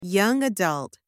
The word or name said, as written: young adult